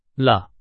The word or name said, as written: 了